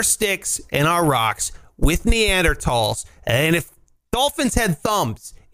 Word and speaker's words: Neanderthals: All like I'm saying is humans, as in Homo sapiens, he used to go to war with Neanderthals, is how you're supposed to pronounce it.